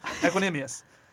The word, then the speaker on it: Equanimeous: Equanimeous. Equanimeous St. Brown, the wide receiver, the oldest of the three brothers.